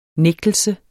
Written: nægtelse

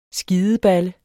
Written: SKIDEBALLE

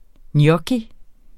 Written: gnocchi

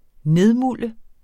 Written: nedmulde

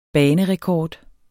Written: banerekord

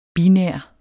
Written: binær

